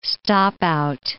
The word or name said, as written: stop-out